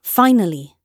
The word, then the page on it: finally: British English